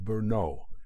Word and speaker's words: Brno: At 11, he received a scholarship to attend the Queen's Monastery and School in the city of Brno, B-R-N-O, pronounced Brno, the largest city in Moravia.